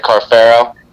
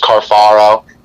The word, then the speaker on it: Carfaro: It's so funny because my immediate family all pronounce it Carfaro, and then aunts and uncles Carfaro. It doesn't matter at the end of the day. It's all the same stuff.